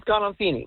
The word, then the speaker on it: Gandolfini: James Gandolfini. If I'm saying it correctly, I hope so. – No, you are.